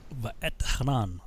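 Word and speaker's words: Va'etchanan: Ve'et Hanan. Okay, so that's how you say it.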